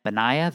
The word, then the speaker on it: Benaiah: So Solomon sent Benaiah, the son of Jehoiada, Benaiah, I said it right the first time, son of Jehoiada, and he fell upon him so that he died.